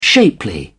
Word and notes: shapely: British English

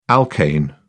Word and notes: alkane: British English

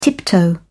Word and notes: tiptoe: British English

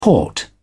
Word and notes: port: British English

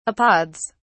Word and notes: apods: British English

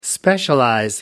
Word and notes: specialize: American English

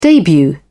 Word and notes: debut: British English

